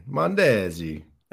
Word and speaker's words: Mondesi: And they pronounce it like it's Italian. Mondesi. Adalberto Mondesi.